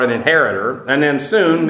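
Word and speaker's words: soon (σύν): And I understand, if you understand, also in spelled in the Greek, you have an S-U-G, but it's pronounced soon. But they didn't like two hard consonants together, so they just phonetically would translate it as soon.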